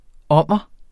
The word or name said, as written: ommer